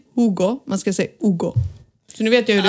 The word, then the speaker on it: Hugo: Men Hugo uttalas Ugo.